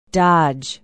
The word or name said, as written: dodge